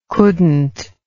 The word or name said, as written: couldn't